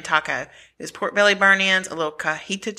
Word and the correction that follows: Cotija: cheese. Did I say that right? – Cotija cheese.